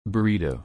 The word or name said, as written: burrito